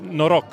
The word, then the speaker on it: noroc: In Romanian, you say cheese, noroc.